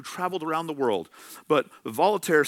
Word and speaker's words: Voltaire: If I want to say it like the American I am, it's Voltaire, but it's probably Voltaire, the French writer.